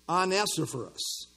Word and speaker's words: Onesiphorus: It starts with on. Onesiphorus. On. Just remember, it's on. Onesiphorus.